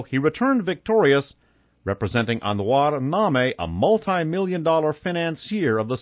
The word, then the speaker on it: Name: Lebanese-Mexican businessman Anwar Name, N-A-M-E, pronounced Name.